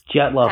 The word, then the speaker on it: Dyatlov: James, I don't really know how to pronounce this, but Dotliov Dyatlov Pass, Pass incident. incident. – Yeah. J- yeah, Dyatlov Pass incident.